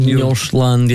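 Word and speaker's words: Nordsjælland: At Tynecastle, with the atmosphere there, you know, the Hearts fans will expect them, and as would it be pronounced Njordiland, is that how you pronounce it? – Njordland, yeah.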